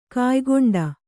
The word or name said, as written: ಕಾಯ್ಗೊಂಡ